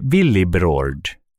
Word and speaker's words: Willibrord: Vi talar förstås om den helige Willibrord. – Uttalar det en gång till. – Den helige Willibrord.